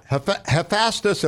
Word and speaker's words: Hephaestus: Hephaestus, am I saying it correctly, the god Vulcan? – Yep.